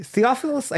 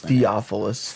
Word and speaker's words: Theophilus: Theophilus. I keep on forgetting how to pronounce his name. – You got it. – Because it has a lot of vowels. – Theophilus.